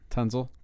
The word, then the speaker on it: Tunsil: Anyway, both Deshaun Watson and Laremy Tunsil. Did I say his name right? – Tunsil.